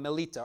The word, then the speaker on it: Melita: And when they were escaped, then they knew that this island was called Melita or Malta. That's how you say it in Greek.